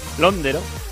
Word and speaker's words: Londero: que al final tenía el acento en la O, Londero, 6-2, 6-3, 6-3.